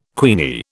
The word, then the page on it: queenie: British English